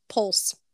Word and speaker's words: Pwyll: Oof, so it's spelled P-W-Y-L-L and it's pronounced pulse.